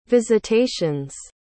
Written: visitations

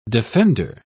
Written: defender

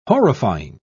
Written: horrifying

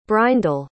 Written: breindel

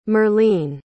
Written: merlene

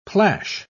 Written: plash

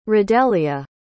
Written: radelia

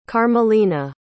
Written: carmelina

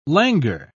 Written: languor

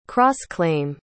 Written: cross-claim

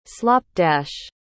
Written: slopdash

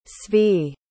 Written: zvi

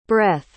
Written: breth

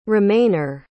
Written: remainer

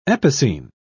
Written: epicene

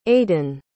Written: aydan

